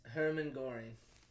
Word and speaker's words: Hermann Göring: How do you pronounce his name? Oh, sorry, right here. – Oh, you're good. Yes, Hermann Göring.